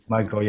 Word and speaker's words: Micheal: Micheal, yes. Yes. – Now we know. – Very American. – Pronounce it the American way.